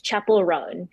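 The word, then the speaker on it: Chappell Roan: So next up, on your list is it Chapel Roan or Chappelle Roan? I show my age here, because I should know that if I were younger. – No, it's okay. I was saying it wrong for months. So it's Chapel Roan.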